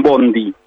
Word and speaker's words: bonde: Escriben bonde, pero pronuncian bondi, digamos así.